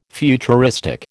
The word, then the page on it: futuristic: American English